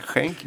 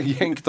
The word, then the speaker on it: Genk: Jag har redan tappat uttalet på Schenk. Vi pratar väldigt mycket om det. Schenk, Genk. – De sa Genk själv. – Ja, men vi säger Genk då.